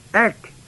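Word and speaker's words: ek (ἐκ): From the dead is the word, are the words ek, e-ek, out from, nekron, n-e-k-r, long o-n, off of which we get our English word necrology and all of that, meaning dead.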